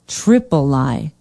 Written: tripoli

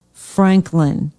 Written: Franklin

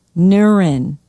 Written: Neuern

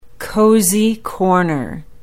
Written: Cozy Corner

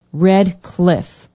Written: red cliff

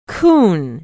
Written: Coon

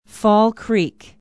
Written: fall creek

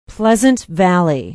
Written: pleasant valley

